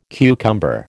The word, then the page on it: cucumber: American English